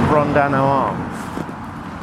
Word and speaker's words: Brondano Arms: No, well, it's pronounced the Brondano Arms, but it's known locally as the Ring.